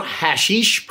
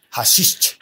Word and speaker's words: Haschisch: The new hashish pronunciation unlocked, but I don't think so. H-A-S-C-H-I-S-C-H. – Hashish.